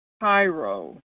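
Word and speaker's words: chairo: This is the Greek word kairo. Kairo, and it is spelled C-H-A-I-R-O. C-H-A-I-R-O.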